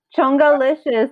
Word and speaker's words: Chongalicious: Changalicious. – Chongalicious. The Chonga girls. – I'm going to pronounce it right. Chongalicious, not yeah. Changa, we spelt it wrong, with an O, Chongalicious.